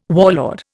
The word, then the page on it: warlord: Indian